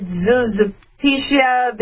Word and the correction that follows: Zaporizhia: the latest, Zaporizhia. Zaporizhia. Sorry.